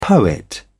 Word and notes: poet: British English

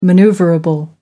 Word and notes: manoeuvrable: American English

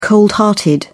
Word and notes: cold hearted: British English

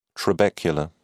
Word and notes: trabecula: British English

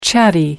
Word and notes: chatty: American English

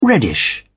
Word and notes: reddish: British English

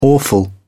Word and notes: awful: British English

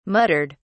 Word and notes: muttered: British English

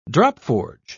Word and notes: dropforge: British English

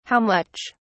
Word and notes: how much: British English

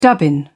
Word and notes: dubbin: British English

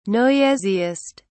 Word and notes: noisiest: British English

